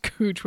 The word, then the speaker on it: Couch: And then I told Molly, I said, it's on a street called Cooch, which is looks like it's couch, but it's pronounced Cooch, which makes a lot of people laugh.